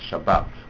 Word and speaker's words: Shabbat: So he said that if you take this Zmiris, Ashoma Shabbos Abedin Mabas, it only rhymes if you pronounce Shabbos Shabbat. And then it rhymes with Bat. But if you say Shabbos and Bas, they'll rhyme, so it's obvious that the Sephardi is right.